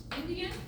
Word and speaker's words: NDN: Indigenous, Native, Indian, NDN, which is an abbreviation that's pronounced Indian, right?